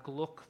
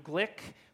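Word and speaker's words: Glück: I understand her last name is pronounced Glick and not Gluck, though I've often pronounced it Gluck previously.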